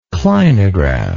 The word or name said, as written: clinographic